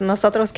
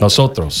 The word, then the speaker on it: Nosotros: And with us today, we have Nostros Cafe. Did I pronounce that correctly? – Yes, Nosotros Cafe. – Nosotros.